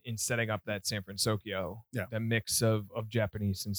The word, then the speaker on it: San Francisco: And then because it was called San Francisco, I think I said that right.